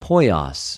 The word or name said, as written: ποιος